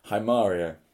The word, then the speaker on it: Haemaria: Haimaria, I think that is the correct pronunciation. – Spelt H-A-E-M-A-R-I-A.